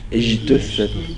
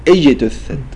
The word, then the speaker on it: اجتثت: هنا البعض ينطق الجيم شين اجتثت هذا خطأ نقول اجتثت حج.